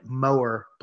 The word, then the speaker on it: Moar: It's called More Blood, and it's spelled M-O-A-R, Mower Blood.